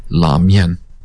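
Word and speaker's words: la mian (拉面): Well, actually, it's very complicated, because in Chinese it's la mian. It's a particular style of noodles. In Japan, they started to call that ramen, which again is the Chinese pronunciation of the Japanese characters.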